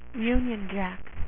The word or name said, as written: union jack